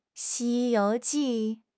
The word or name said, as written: Xi1 you2 ji4